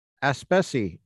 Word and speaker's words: Aspesi: We have with us Cara Aspesi.